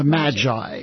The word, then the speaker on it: Magi: The person who literally wrote the book, the star of the, do you pronounce it with a hard G or a soft G? Is it soft G. – The Magi, yeah.